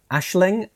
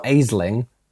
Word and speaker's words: Aisling: Ashling, it's not Aisling.